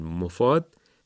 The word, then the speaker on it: مفاد: بنابراین مفاد تشدید نداره. پیشنهاد میشه، توصیه میشه که به همین ترتیب تلفظ بفرمایید. میم ضمه داره، مفاد نه مفاد و بدون تشدید.